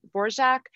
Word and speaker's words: Dvorak: So early on on, she did want it to be pronounced um, Borjak, and I don't know that anybody ever did. – So the the, com- the very common pronunciation became Dvorak, and even early on, she seemed to not she seemed to waver on how she wanted to spell it.